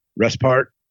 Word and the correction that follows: Restrepo: Oh, Restrepo.